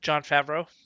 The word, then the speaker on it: Jon Favreau: Jon Favreau.